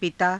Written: pitaa

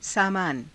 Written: saamaana

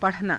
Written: pa_rhanaa

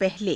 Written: pahale

